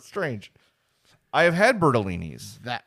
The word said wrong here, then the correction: Bartolini's: Bartolini's. I'm sorry, I said it wrong.